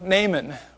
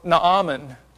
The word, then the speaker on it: Naaman: It's the story of Naaman, or Naaman, however you choose to pronounce his name. It's a double A. Typically in that setting, it would be Naaman. But regardless, in my boyhood, I called him Naaman.